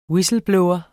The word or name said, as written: whistleblower